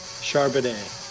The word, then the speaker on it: Charbonnet: Charbonnet.